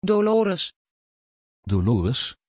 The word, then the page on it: Dolores: Dutch